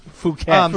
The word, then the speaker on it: Phuket: That's pronounced Phuket.